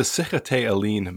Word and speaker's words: Sikhote-Alin: Like, I, I know I'm not going to pronounce this right, but spelled like the Seacoat Allen Mountains, or how how would you pronounce that? And what sure, was that uh, landscape like? Yeah, so it's the Sikhote-Alin Mountains,